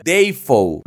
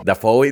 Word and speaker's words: Dafoe: En 1975 y, oh, sorpresa, su nombre real no es William Dafoe. Él lo pronunciaba William Dafoe. – así como, oh. Oh". – Ya después dice que como todo mundo se confundía, pues ya lo comenzó a pronunciar como Dafoe